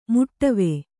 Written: ಮೊಡವೆ

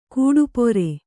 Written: ಗಡಪಾರೆ

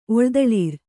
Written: ಒಳ್ದಳಿರ್

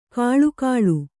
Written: ಘಳಘಳ

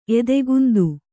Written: ಎದೆಗೊಂತ

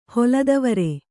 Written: ಹೊಲದವರೆ

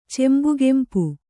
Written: ಚೆಂಬುಗೆಂಪು

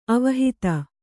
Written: ಅವಹಿತ

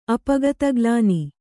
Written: ಅಪಗತಗ್ಲಾನಿ

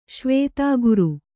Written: ಶ್ವೇತಾಗುರು

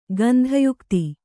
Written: ಗಂಧಯುಕ್ತಿ

